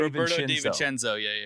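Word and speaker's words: Roberto De Vicenzo: Roberto DiVincenzo. – Roberto DiVincenzo.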